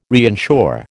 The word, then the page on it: reinsure: American English